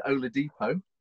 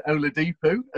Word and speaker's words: Oladipo: So I'm now going to concentrate, and it's Victor Oladipo. – There you go. – As opposed to Oladipo, as I normally pronounce it, much to Mikey's pleasure.